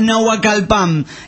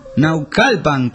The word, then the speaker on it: Naucalpan: Dice, no se pronuncia Nahuacalpan, estado de México. Se pronuncia Naucalpan, qué carajo.